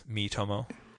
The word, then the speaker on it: Miitomo: Miitomo? – Miitomo, yes. – M-I-I-T-O-M-O. – Yeah, so if it's pronounced like the Miis were on the Wii, Yeah.